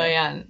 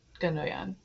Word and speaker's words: Gündoğan: G is a little softer. – Oh, thank you. – Gündoğan, like Gündoğan. Yeah. – Okay. And how do you know that? – It has a similar suffix to the president of Turkey. It's pronounced Erdogan.